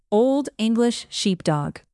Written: old english sheepdog

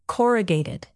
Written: corrugated

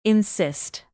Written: insist

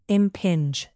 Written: impinge

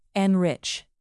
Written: enrich

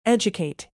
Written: educate

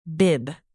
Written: bib

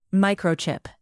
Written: microchip

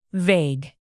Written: vague

